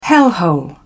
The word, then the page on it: hellhole: British English